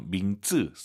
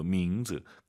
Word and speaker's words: 名字: 이름 발음하실 때요, 밍즈, 쌍지읒으로 정말 많이 하시더라고요. 밍즈, 이성경성, 지읒 느낌으로 밍즈, 까먹었다, 잊어버렸다, 忘了, 잊지 마시기 바랍니다.